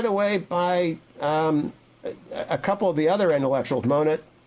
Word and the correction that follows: Moten: if I'm pronouncing that right. – Moten, yeah.